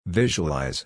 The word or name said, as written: visualize